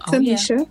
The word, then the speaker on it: Kamshia: But that story is near and dear to me, having a unique name like Kamshia.